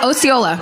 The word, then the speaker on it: Osceola: O-S-C-E-O-L-A. Osceola. I said it first, Osceola. I said it before you. I already know and I said it before you. Osceola. – Osola. – Osola? Ohio? Osola? Osceola.